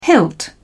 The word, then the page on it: hilt: British English